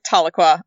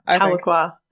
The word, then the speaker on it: Tahlequah: We're going with Tahlequah. – Tahlequah.